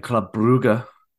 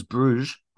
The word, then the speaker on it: Brugge: And then Club Brugge, often mispronounced as Bruges. Club Brugge.